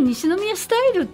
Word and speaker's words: 西宮流: まあ、皆さん多分西宮スタイルって西宮流と書いてね、はい、西宮スタイルと読むのはご存知かなと思うんですが、はい、改めてちょっと概要を紹介していただけますか。